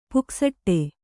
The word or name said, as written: ಪುಕಸಟ್ಟೆ